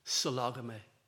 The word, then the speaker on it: sologamy: It's spelt as it sounds, S-O-L-O-G-A-M-Y, sologamy.